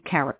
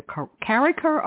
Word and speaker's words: Karraker: I hope I'm pronouncing the name properly. K-A-R-R-A-K-E-R. Hugh Carriker. It's either Carriker or Carriker. I'll ask him when we get on board here.